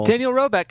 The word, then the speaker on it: Roebuck: Help me with the pronunciation of of his last name. – Daniel Roebeck.